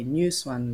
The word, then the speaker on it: Nhu Xuan: And if I wanted to say my full name, I would say New Swan, like a new swan, you know.